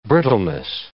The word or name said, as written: brittleness